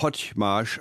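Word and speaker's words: Hagymás: Einer schreibt sich H-A-J-M-A-S, der andere H-A-G-Y-M-A-S. Da würde man Hotchmarsch aussprechen, wäre auch möglich.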